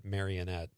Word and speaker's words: Marinette: Well, I live in Marinette. Everybody pronounces it Marionette.